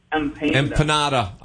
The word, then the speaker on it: empanada: And then how was I pronouncing this incorrectly for the Mundi? – I thought you were calling that empanada. – Empanada. It's empanada. – Yeah, because I was like panda.